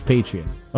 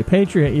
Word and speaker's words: Patreon: Or is it Patreon? I think it's Patreon. – Okay, Patreon.